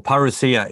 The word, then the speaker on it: parousia: As you can see, I mean, I've got logos behind me that says the word parousia, or parousia is actually the way to pronounce it. In Australia, we just said parousia. It's an Aussie thing.